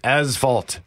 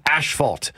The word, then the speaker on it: asphalt: Note, there is no H after the S. Mm-hmm. It is pronounced asphalt, not asphalt.